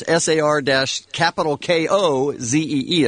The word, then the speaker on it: Sarkozy: President Nicolas Sarkozy is SAR-KOZEE. That's not how it's spelled, but that's how you say it.